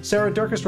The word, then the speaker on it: Durkis: Sarah Durkis, Rhymes with Circus,